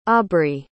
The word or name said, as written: aubrey